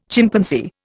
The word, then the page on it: Chimpanzee: Indian